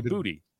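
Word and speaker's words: Boutte: Like everyone said his name wrong for like 2 years, and then someone finally asked him and was like, hey, it's actually Booty.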